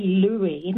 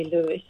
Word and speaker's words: Louis: I say Louis. My father was actually called Louis, and so perhaps, and one of my grandsons is Louis. Yeah. So I tend to say Louis, Yeah, some people do say Lewis, I think. – I've heard it pronounced both ways. – Yeah, I feel Lewis is when it's spelt L-E-W.